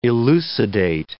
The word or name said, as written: elucidate